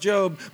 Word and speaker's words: Job: It's pronounced Job.